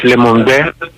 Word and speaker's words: Le Monde: Είναι χαρακτηριστικό ότι σύμφωνα με δημοσίευμα της γαλλικής εφημερίδας Le Monde, το είπα καλά με προφορά? – Πολύ καλά το πες.